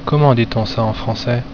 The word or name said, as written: comment dit on